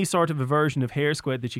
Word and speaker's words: Hare Squead: Hare Squead.